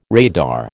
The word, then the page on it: radar: American English